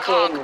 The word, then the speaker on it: conch: I had conch fritters and pina coladas with Rick Steves last night. – Don't even say his name. It's Cog, by the way.